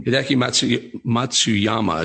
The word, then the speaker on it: Hideki Matsuyama: Hideki Matsuyama, is that how to pronounce it? I believe that's how I pronounce it. – That's correct.